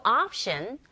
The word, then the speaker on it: option: There's no option but to turn around. 옵션이 아니라, 아, 철자 오에, 강세가 가는 경우에 오가 아닌 아에 가까운 발음으로 들리고 발음한다는 거 아시죠?